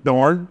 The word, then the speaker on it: Dorn: Myron Dorn, M-Y-R-O-N and D-O-R-N.